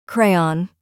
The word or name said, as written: crayon